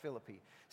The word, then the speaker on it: Philippi: The Apostle Paul left Troy, Turkey for Philippi, or Philippi, however you want to pronounce it. I guess we don't say Philippians, so we say Philippians, so it's Philippi. So he left for Philippi in 60, yes it is, it's pronounced Philippi, 60 to 61 AD, around 30 years after Christ ascended to heaven, and in a not-so-chance prayer meeting, A church plant began.